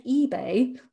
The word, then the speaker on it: Ibeyi: A couple of sisters, they're twin sisters and they're called eBay, but not in the eBay, E-B-A-Y sense. It's spelled I-B-E-Y-I.